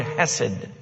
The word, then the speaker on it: hesed: It's a translation of a rich two-syllable Hebrew word, hesed.